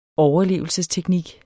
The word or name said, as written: overlevelsesteknik